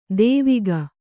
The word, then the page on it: ದೇವಿಗ: dēviga